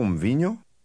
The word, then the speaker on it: mvinyo: Au mvinyo? Au mvinyo? Au mvinyo? – Did you include the slight m sound at the beginning of the word for wine?